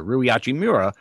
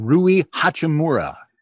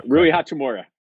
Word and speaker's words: Hachimura: Well, Russell joins your impact player that you drafted last year, Rui Hachimura, out of, and I'm saying that wrong, Rui Hachimura. I have to say right? – it. – Rui Hachimura. Yeah, you're right.